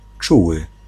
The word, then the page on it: affectueux: /a.fɛk.tɥø/; France